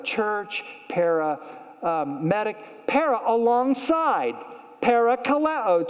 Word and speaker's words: parakaleo: And the Greek word is the word parakaleo.